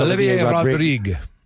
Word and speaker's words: Olivier Rodrigue: Olivier Rodrigue.